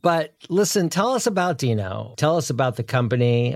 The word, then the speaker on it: Deno: Deno, as in Fred Flintstone, is spelled D-I-N-O, but it's pronounced D-E-E-N-O.